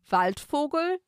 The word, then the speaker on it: Waldfogel: Waldfogel. I just want to say this is a German name, I believe. And in German, it would be pronounced Waldvogel. But it's, I think, Waldfogel.